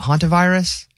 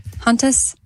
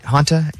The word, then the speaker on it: hantavirus: The other story was that that hantavirus that's happening. That's how you say it? – Hantus? – Yeah. Hanta. H-A-N-T-A. – Oh, I thought there was an S.